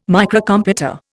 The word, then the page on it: Microcomputer: Indian